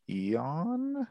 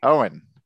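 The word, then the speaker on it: Eoin: Is it Eon? No? Did I miss it? – Owen. – Owen. – Yeah, pronounce it like Owen.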